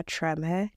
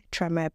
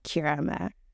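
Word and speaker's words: Kyeremeh: And since K-Y-E is a odd combination of letters in this part of the world, well, everywhere else except Ghana, people don't know how to pronounce it. So when they see, like, when they see it written, they just do like Kirama.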